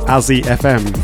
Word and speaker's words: Azure: It is pronounced Azzy FM, for those that did not know. I know that's always a good drum and bass pronunciation. I was always calling him Azzy FM. It is Azzy FM.